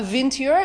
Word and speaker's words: vintner: Vintner. With an N-E-R. – V-I-N-T-N-E-R. Vintner? – Ner.